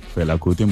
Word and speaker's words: Fela Kuti: Fela Kuti. – Fela Kuti, muy bien.